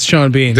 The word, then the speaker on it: Sean Bean: Shout out to Sean Bean.